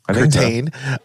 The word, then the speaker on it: Curtain: Rini, Sticky, Kate, and Constance must infiltrate the mysterious LIVE, L-I-V-E, Institute to discover the truth behind the crisis when Headmaster, the sophisticated Dr. Curtin, did I say that right? Curtain, appears to be behind the worldwide panic.